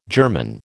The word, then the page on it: germon: American English